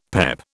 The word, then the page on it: PEP: British English